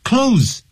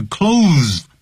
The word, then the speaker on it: clothes: I gave away some of my clothes，记住 clothes 千万不要念是 clothes，那个 th 不念出来的close 跟关门的那个 close the door 那 close 的发音是一模一样。我要把我一些衣服呢捐出来，捐给慈善机构。Number four, formulate a plan or strategy or policy，规划计划或者是策略或者是政策。The original plan is not working.